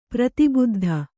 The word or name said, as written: ಪ್ರತಿಬುದ್ಧ